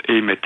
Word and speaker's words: Eymet: Mais je crois qu'il prononce Aimet. Faudrait le demander à la madame de l'atelier d'épicure, là, parce que... Je pense qu'il prononce, c'est E-Y-M-E-T, donc c'est une bastide.